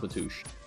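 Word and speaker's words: Matúš Bogus: Matush Bogus. Did I get it? – Yeah, basically. – Matush? Or less. Bogus. – Yeah, that's how you pronounce Matush.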